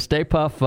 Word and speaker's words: Stay Puft: He's got the big did I say it right this time, Bobby? The big Stay Puft. Yes. Did I say it right yes. this time? The Stay Puft.